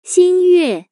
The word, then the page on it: 新月: xin yue; Chinese